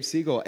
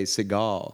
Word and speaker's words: Segal: Yes, Gabe Segal. And I am so proud that you could pronounce the last name. Most people say Segal.